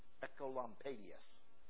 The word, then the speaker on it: Oecolampadius: His name is Johannes Ecolampadius, and it's spelled O-E-C-O-L-A-M-P-A-D-I-U-S, but you pronounce it O-E as Echo.